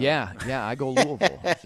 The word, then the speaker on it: Louisville: I have transitioned over to pronouncing it Louisville. I do it like the locals. I know you go Louisville. I'm not saying you're wrong.